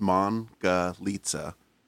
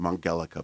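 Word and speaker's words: Mangalitsa: It's the Mongolitsa pig, and it's pronounced Mongolitsa. And in Hungary, they're a pig. They call them the Mongelica pig.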